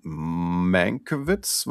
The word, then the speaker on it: Mankiewicz: Das Drehbuch hat zum zweiten Mal nach, man lebt nur zweimal, glaube ich, nicht Richard Maybaum geschrieben, sondern Tom Mankiewicz, wird es, glaube ich, ausgesprochen.